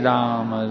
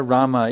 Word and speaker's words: Rama: Hare Rama, Hare Rama, Rama Rama, Hare Hare. Now on Ram, you can say Ram or Rama, either one, on Ram.